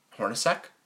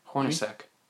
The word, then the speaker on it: Hornacek: the end of the Derrick Rose era, the start of the Jeff Hornick era. – Did I say that right? – Jeff Hornacek? – Hornacek. Is that how you say it? – Yeah, yeah. Like, yeah.